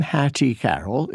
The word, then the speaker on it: Carroll: So the name Hattie Carroll is stressed on the first syllable, not on the second.